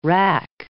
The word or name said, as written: wrack